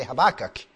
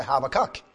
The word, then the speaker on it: Habakkuk: Number one is, if you disagree with me how to pronounce this name, that's fine. I say Habakkuk, you might say Habakkuk. Some might even say Habakkuk. But I'm going to call him Habakkuk, and hopefully you'll fit in with that.